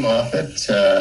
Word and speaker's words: Moffett: It's Moffett? Moffett, that's it. All right. Well, how about you introduce yourself, Mr. Moffett? – My goodness. Ken Moffett.